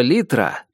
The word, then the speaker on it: литра: Однажды я опрометчиво назвал ее «Литрой», и мистер Беркет поправил меня, разъяснив, что слово «литра» произносится с ударением на первый слог и употребляется только в жаргоне пьяниц в сочетаниях типа «взял пол-литру». Даже без фаршированной индейки и только с морковкой в качестве овощного гарнира ужин был замечательный.